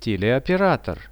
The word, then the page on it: teleoperator: Russian